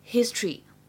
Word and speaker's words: history: 也是完全 OK 的，大家呢可以参考一下历史这个单词，它也是呢有两种发音，history 和 history 都对。